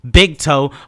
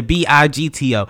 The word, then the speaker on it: BIGTO: For y'all who keep getting my fucking name wrong, it's the BIGTO. It's not the BIGTO. It's not the BIGTOE. It's not the BIGTOO. It's the BIGT to the fucking O.